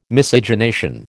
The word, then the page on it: Miscegenation: American English